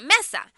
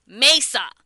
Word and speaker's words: Mesa: So we all know that Mesa is the third largest city in Arizona, and it's been, you're supposed to pronounce it Mesa, but the white natives call it Mesa.